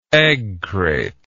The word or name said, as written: eggcrate